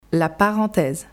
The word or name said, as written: La parenthèse